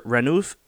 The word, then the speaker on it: Ranulph: So, in what year did explorer Sir Ranulf it looks like Randolph, but they missed the D. R-A-N-U-L-P-H. – Ranulph. – In what year did explorer Sir Ranulph Fiennes run 7 marathons on 7 days on 7 continents? – Oh, wow.